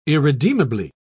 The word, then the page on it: irredeemably: British English